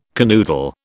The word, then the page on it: canoodle: British English